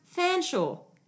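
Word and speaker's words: Featherstonhaugh: It looks on the page like his name is Featherston Hoare, right? But I recently learnt it's pronounced Fanshawe.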